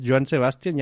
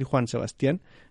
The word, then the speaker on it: Joan Sebastián: Mm, Joan, fíjate, este ya, lo sé pronunciar bien, es Joan Sebastián, ya no le voy a decir Juan Sebastián.